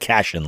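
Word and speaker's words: Cashion: Cashin, that's C-A-S-H-I-O-N. I may be mispronouncing it, but I believe it's like Cashin, like fusion, like that.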